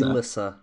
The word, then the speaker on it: Elyssa: We need to be careful with our pronunciation. That just hit me. There's Alyssa Valerion / Targaryen, filled with A-L-Y-S-S-A, and then there's Elyssa. – Elyssa, yeah.